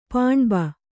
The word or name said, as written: ಪಾಣ್ಬ